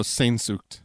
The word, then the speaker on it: Sehnsucht: Oh, Sehnsucht.